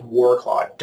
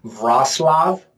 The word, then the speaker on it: Wrocław: So we flew straight in and then worked our way down to a city called, it looks like Wroclaw, W-R-O-C-A-C-L-A-W, but it's pronounced Wrocław.